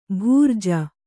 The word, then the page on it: ಭರ್ಜಿ: Kannada